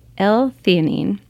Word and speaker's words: L-theanine: L-theanine.